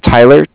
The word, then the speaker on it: Tyler: My name is Tyler, T-Y-L-E-R. – Okay. – And I'm calling with Cardiff. It's spelled C-A-R-D-I-F-F.